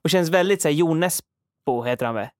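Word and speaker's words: Jo Nesbø: jag tror på Jonesbo om man uttalar det så.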